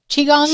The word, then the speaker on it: Qigong: There's also Qigong. Qigong. – Sure, you said it right.